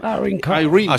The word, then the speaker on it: Irene Cara: Irene Cara.